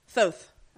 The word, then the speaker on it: Thoth: Thoth. I was right about Thoth.